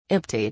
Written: emptied

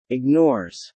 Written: ignores

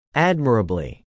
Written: admirably

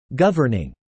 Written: governing

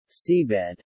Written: seabed

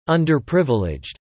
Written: underprivileged

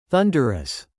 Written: thunderous

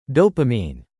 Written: dopamine

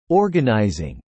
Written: organising

